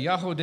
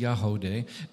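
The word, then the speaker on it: Yagody: Yahodeh is spelled for the audience. It's Y-A-G-O-D-Y, but it's pronounced Yahodeh.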